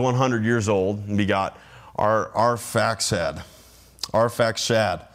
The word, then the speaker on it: Arphaxad: And that's actually spelled two different ways. One's got an X and the other one's an SH, but it's Arphaxad. I've kind of butchered it a couple times, but that's how we're going to say it. Arphaxad.